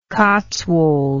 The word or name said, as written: Cotswold